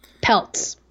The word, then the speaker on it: Peltz: So he's now Brooklyn Pets Beckham. Peltz. Peltz. – I've been saying that wrong.